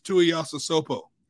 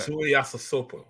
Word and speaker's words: Tuiasosopo: Marcus Tuiasosopo, quarterback. – Tuiasosopo, yes.